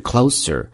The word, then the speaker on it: close: Don't come any closer, closer, 注意啊，在这儿 close, 做名词的时候它读的是思结尾 close, 比较级是 closer, 那么做动词的时候读的是 z, 比如说 close the door, 关门，所以是不一样的发音。